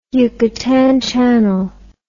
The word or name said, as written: yucatan channel